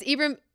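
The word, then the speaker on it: Ibram: His name is Ephraim. – Yes. – Yes. Okay. I do not pronounce things correctly. I'm telling you this and the listeners. – Yeah. It's Ephraim X. Kendi.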